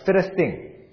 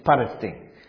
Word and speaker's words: Palestine: 那照希腊语念的话是 Philistine，但是拉丁文念的时候不是念成 F 的音 Philistine，它是 Palestine，所以变成巴勒斯坦。